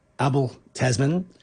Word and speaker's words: Abel Tasman: And there was a Dutch bloke standing next to me and he explained the correct pronunciation is Abel Tasman.